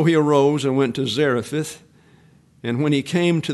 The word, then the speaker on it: Zarephath: So with the forecasted drought now underway, the Lord commanded Elijah to leave the brook, travel northwest to the coastal town of Zarephath, is the way it's best pronounced, where the widow will provide him his logistics, and that's verses 3 through 11.